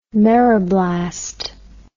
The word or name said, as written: meroblast